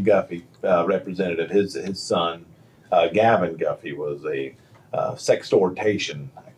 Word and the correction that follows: sextortion: Did I say it right? – Sextortion.